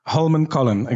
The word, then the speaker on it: Holmenkollen: It's Holmenkollen? – Holmenkollen, exactly.